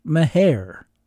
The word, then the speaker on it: Mexia: spelled M-E-X-I-A. Mexicans pronounce that Mejia, but I'm told most Texans pronounce it Mejia. And my wife, who's from Muleshoe, Texas, says it's pronounced Meher.